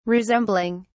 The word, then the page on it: resembling: British English